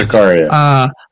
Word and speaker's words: Zakaria: Is he related to Zachariah that Brad talked about earlier? – Yeah, it was the one that he mispronounced terribly earlier. – Yeah. Zakaria.